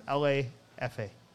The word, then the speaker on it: LAFA: That's not a Spanish pronunciation, by the way. It's just four letters. LAFA.